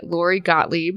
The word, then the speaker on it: Lori Gottlieb: By Lori Gottlieb.